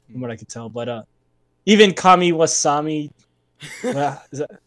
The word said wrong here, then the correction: Kamaswami: Uh, Ka- How do you say that? Kamaswami.